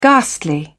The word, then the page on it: ghastly: British English